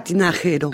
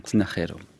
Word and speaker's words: Tinajero: Alors je ne sais pas comment on prononce. Cesarea. – Cesarea Tinajero. – Ah, Tinajero.